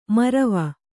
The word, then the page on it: ಮರವ: marava